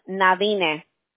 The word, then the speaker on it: Nadine: Mi primer nombre es difícil para pronunciarlo en español, pero es Nadine, si la quieres decir así.